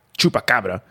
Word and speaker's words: Chupacabra: The Chupacabra, in Spanish pronunciation, Chupacabra, from chupar, or to suck, and cabra, goat.